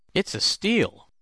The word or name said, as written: it's a steal